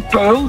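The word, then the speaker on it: Puyl: Het is gewoon puil, maar het wordt dus U-Y, dus U lange E wordt het geschreven.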